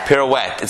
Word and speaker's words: pirouette: Pirouette.